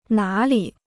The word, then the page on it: 哪里: nǎ lǐ